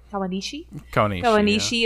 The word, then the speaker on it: Kawanishi: But my feelings on Kawanashi is like they're all over the place all throughout this. My first note is Kawanashi is a snake. Is that how they say his name? Kawanishi yeah.